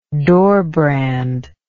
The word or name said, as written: doorbrand